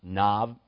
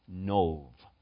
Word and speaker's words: Nov: Now, I realize as you look at this, you might think it should be pronounced Nob or something like that. But in Hebrew, it's Nov.